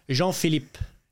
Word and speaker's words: Jean Philippe: Jean Philippe.